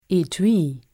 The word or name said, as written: Etui